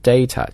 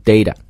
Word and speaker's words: data: 英式英语呢这个 t 会发得非常的清楚，就会发成 data 啊，但是呢美式英语这个 T，我们以前讲过，它发音的时候会把它发成一个弹舌的一个 flap T，那它在读的时候会读成 data data， so data or data 啊，这美式英语 data。